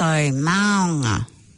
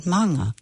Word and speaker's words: maunga: They, you know, the AU is O, not owl. And so maunga, you know, it's just, it sounds, it's so twangy and it's so unnecessary. Maunga.